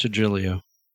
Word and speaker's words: Trujillo: I can say Robert Trujillo, by the way. I am capable of saying it. – Pronounced Trujillo.